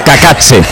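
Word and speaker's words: Cacace: La caída de Liberato Kakatse, he tenido que buscar cómo se pronunciaba este futbolista para no cometer errores. Y según la IA es Kakatse.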